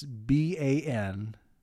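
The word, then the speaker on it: Banastre Tarleton: Bannister Tarleton. – That is the most British name that you could come up with. – I believe it's pronounced Bannister. It's Bannastre.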